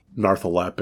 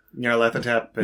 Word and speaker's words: Nyarlathotep: So again, this is about as old as you can get without summoning, you know, Narthalep and... – Nyarlathotep.